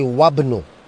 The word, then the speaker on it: wabnu: Wabnu, now here I don't say Wa Ibnu, I say Wabnu. I don't pronounce the Hamza.